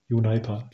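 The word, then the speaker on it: Uniper: Werden die Unipa ausgesprochen oder wird das Englisch ausgesprochen? Unipa. Ich glaube, das ist Deutsch ausgesprochen wird, Unipa.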